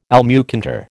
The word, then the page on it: almucantar: American English